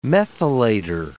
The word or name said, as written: methylator